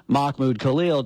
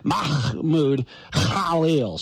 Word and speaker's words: Mahmoud Khalil: Instead of saying Mahmoud Khalil, it's supposed to be Mahmoud Khalil.